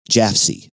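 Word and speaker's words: Jafsie: The 72-year-old Dr. Condon would soon come to be known to the world by the pronunciation of his initials, JFC, as Jaffsy.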